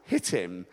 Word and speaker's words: Shittim: It's actually spelled S-H-I-T-T-I-M, but it's pronounced Hittim. It's just like in Spanish, you say, yo hablo, hablo, yo hablo español, or yo hablo. You drop the H because it's silent. And this is Hittim, it's not with the S.